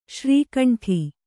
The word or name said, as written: ಶ್ರೀಖಂಡ